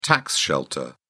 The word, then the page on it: tax shelter: British English